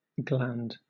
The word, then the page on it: gland: British English